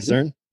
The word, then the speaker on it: CERN: You can say it, CERN. – CERN. That's how you say it, CERN? Okay. – CERN, yeah.